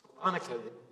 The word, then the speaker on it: Salonica: Why do I have trouble saying Salonica? The emphasis on the second syllable.